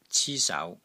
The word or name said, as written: CI1 SAU2